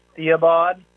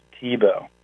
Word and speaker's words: Thiebaud: It looks like Theobald, but actually it's pronounced Thiebaud.